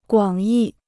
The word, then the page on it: 广义: guǎng yì